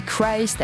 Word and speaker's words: Christ: En effet, on dit Christ avec le son I.